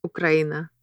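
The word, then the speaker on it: Ukraina (Украина): And how do you pronounce, how would you say Ukraine in Russian? – Ukraina.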